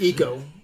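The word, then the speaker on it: Iko: Is that how it's pronounced? Eco Uwais? – I know it's Eco. – Eco, so, okay.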